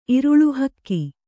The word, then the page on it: ಇರುಳು ಹಕ್ಕಿ: iruḷu hakki; Kannada